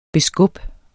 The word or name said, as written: beskub